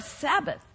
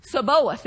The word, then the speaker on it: Sabaoth: You know, I always thought that was the Lord of Sabbath. It's not. Sabaoth is how you say it, I guess.